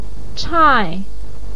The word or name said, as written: chai4